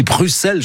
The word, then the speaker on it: Bruxelles: Oui, on prononce pas le X, hein, bah, sinon je vais me faire rouspéter par Émilie de Kegel. Elle va dire, non, on dit Bruxelles chez nous.